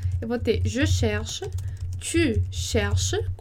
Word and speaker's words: cherche: Para você observar que a gente não pronuncia nem o S do tu, então a gente vai falar tu cherche, e nem o NT do il, elle no plural. Então a gente vai dizer il cherche, elle cherche. E aí a pronúncia vai ficar a mesma para todas essas pessoas que estão aí listadas.